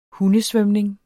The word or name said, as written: hundesvømning